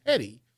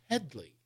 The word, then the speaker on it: Hedley: Which is a takeoff on the actress Hedy Lamarr, and they keep mispronouncing, they keep calling him Hedy, and he's like, it's Hedley.